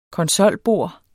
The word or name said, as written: konsolbord